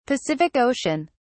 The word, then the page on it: Pacific Ocean: British English